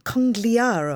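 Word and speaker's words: Conigliaro: Tony Congliaro, I think that's how you pronounce his name. – Yes, that's very good.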